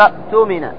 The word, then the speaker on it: اؤتمن: اؤتمن، عندما تنطق أنت حال الوصل تنطق بهمزة القطع وتحدث همزة إيش؟ الوصل، واضح؟ فتقول الذي اؤتمن، الذي اؤتمن، الثانية تنطق والأولى تحذف. عند الابتداء أيهما تنطق؟ تقول اؤتمن، اؤتمن، مثل ايتو كما تقدم معنا، ايتو أصلها ائتو، تقول ايتو.